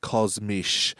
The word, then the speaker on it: kosmische: I haven't looked it up, but it's like kosmisch, like K-O-S-M-I-S-C-H-E, kosmisch.